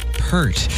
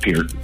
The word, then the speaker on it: Peart: It is pronounced Peart, not Pert.